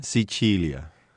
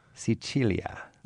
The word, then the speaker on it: Sicilia: How do you say Sicily in Italian? Sicilia. Sicilia. – Yes, this is the name in Italian that we say Sicilia.